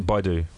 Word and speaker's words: Baidu: Baidu.